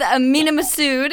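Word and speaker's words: Mena Massoud: So being roommates with Mina Massoud, is that how you say it? – Yes.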